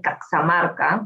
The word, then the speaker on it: Caxamarca: de Caxamarca, ¿no? Como se pronunciaba antes su nombre,